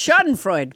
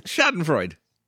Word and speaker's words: Schadenfreude: wait, how did you just pronounce it? – I don't know. Grishunkdit. What was it? – No, it's Schadenfreude. – Schadenfreude.